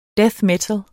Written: metal

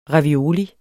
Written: ravioli

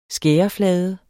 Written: skæreflade

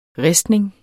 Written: ristning

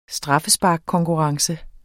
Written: straffesparkskonkurrence

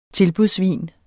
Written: tilbudsvin

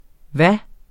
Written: hvad

